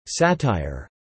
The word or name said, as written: satire